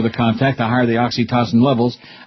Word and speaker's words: oxytocin: Just like oxytocin.